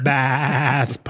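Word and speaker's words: Ba'ath: I just know he's from the bath party. Is that how you say it? – I think that is how you say it, with the funky Klingon apostrophe in the middle of it.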